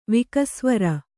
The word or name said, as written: ವಿಕಸ್ವರ